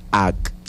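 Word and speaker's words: a'k: Y se pronuncia de esta manera. Ak. – Ak. – Muy bien, lo estás haciendo muy bien. Vamos a repetirlo una vez más. Ak.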